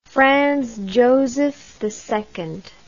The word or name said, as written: Franz Joseph II